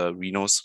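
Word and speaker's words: Rhinos: Ähm, aber die äh, Erlanger Rinos, äh, Rinos, sorry, ich muss das in Englisch aussprechen, äh, VC Freudenberg haben beide zurückgezogen.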